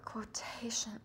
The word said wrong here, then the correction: Quotatia: Am I pronouncing her name correctly? – Quotatia. – Quotatia.